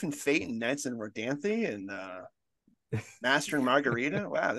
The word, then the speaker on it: Rodanthe: It's I think it's Knights and Rodanthe, but we pronounce it Knights and Rodan for like 20 minutes on the podcast, and then I was like, I think we're not pronouncing this right.